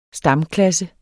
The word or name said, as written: stamklasse